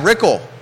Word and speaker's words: Rickle: Hell, Board of Supervisor candidates Michael Pruitt, TJ Fadeley, Brad Rickle, rhymes with pickle.